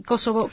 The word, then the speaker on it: Kosovo: la verdad es que me dan Kosovo, es una cosa, Kosovo.